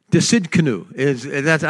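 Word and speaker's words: Tsidkenu: Try to pronounce that one. You kind of do pronounce the T. Desidkenu. I've heard it a couple of different ways, but that sounds logical.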